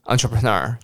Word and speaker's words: entrepreneur: 但是你每一个音节都念得很清楚，entrepreneur，你不要念成四拍，你念一拍 entrepreneur 就好了，entrepreneur，entrepreneur。